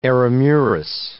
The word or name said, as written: eremurus